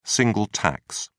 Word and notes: single tax: British English

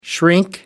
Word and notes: shrink: American English

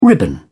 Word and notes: ribbon: British English

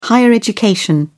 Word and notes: higher education: British English

